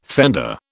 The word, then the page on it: fender: British English